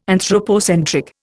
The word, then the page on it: anthropocentric: Indian